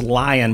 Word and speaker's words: LAION: It's pronounced Lion, but it's LAION .AI.